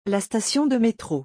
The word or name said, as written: la station de métro